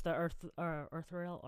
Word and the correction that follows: ethereal: or I can't pronounce ethereal.